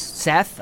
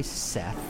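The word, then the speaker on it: Ceth: Seth? All right. Hey, Seth. – Yeah. It's C-E-T-H. Seth. It's a soft C.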